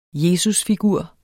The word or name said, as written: jesusfigur